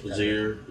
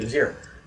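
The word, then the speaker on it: Jazeera: I can never say it correctly. Speech. – Zier. – Yes, thank you, Zier.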